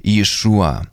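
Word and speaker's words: Иешуа (ישוע): Это еврейское имя и произносится на языке оригинала как Иешуа.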